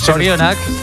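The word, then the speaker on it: Zorionak: Sorionak.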